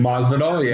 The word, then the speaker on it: Masvidal: Well, Masvidal? That's how you say his name? – Masvidal, yeah.